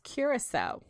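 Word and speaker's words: Curaçao: So it's pronounced Curacao, which Curacao. Curacao.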